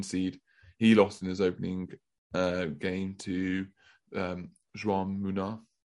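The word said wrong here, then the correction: Jaume Munar: Jaume Muná.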